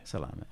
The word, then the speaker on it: salame: So you said salame. – Salame. – Salami. – Yeah, because an E is pronounced as an S salame. In Italian. So salame.